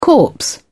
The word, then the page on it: corpse: British English